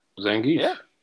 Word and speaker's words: Zangief: Is that Zangief? Am I saying that right? – You got it. Zangief.